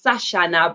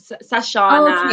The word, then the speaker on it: Sachanna: It's Sachanna, but Sachanna, exactly, okay.